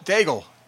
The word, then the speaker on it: Daigle: Daigle, yes. – It's Daig. It's pronounced Daig. It looks like it's spelled Daigle. But it's Daig.